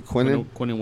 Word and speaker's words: Quinnen: Quinnen, is that how you say it? – Quinnen Williams.